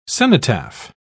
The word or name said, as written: cenotaph